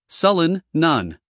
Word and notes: sullen: ˈsʌlən

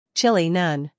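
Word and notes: chilly: uk ˈtʃɪli; en-us ˈtʃɪli

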